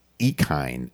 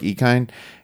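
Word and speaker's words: Ikhine: Wide receiver Nick Westbrook, I believe it's Ekine. Am I saying that wrong, Dave? – I think that's correct. – Westbrook Ekine had 8 targets and pick him up for the A.J.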